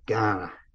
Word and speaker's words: Gan'na: There's a word Gan'na, G A N apostrophe N A, Gan'na is how you say it,